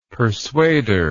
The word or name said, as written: persuader